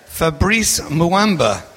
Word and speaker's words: Fabrice Muamba: Fabrice Muamba.